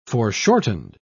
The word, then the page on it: foreshortened: British English